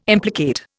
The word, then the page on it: implicate: Indian